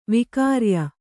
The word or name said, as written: ವಿಕ್ರಯಿ